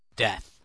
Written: death

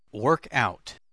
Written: workout

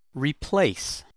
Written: replace

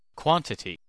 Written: quantity

